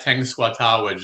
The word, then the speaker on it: Tenskwatawa: And you got Tenskwatawa just right.